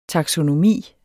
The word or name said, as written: taksonomi